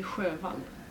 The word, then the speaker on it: Sjöwall: Maj Sowal. Am I pronouncing that at all right? – Mm-hmm. – Per Wallu, Wahloo, and Maj, Maj. What was the last name? – Per Wallu and Maj Sjöwall.